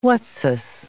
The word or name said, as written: whatsis